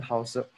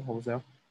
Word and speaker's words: Housel: So today we are still on The Psychology of Money by Morgan Housel. I need to get the name right. Is it Morgan Housel? – Morgan, yeah. Housel, I guess.